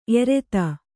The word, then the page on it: ಏರ್ತೆ: ērte